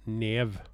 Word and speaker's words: Niamh: Hon heter då, sånt som jeg har lært meg å uttale dette her, Nev Algar, og hennes førnavn det staves da N-I-A-M-H. – Og det uttales visst da Nev.